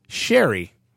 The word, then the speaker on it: Sheary: And also, apparently we've been pronouncing Connor Sheary's name wrong. – Have we? – Yeah. He came out today. Apparently, after 3 years, he finally just said, guys, you're pronouncing my name wrong. It's pronounced Sherry.